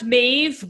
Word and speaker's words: Medb: Did you say it was Maeve or Maeve? How's it spelled? – Pronounced Maeve, but it's spelled M-E-D-B. It's an Irish name.